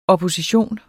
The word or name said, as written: opposition